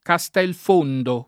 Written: Castelfondo

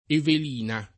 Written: Evelina

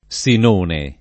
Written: Sinone

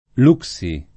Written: Luxi

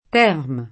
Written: Thermes

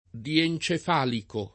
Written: diencefalico

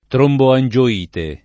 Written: tromboangioite

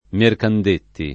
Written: Mercandetti

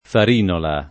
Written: Farinola

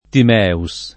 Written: Timeus

